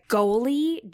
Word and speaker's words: Goli: Goli, G-O-L-I.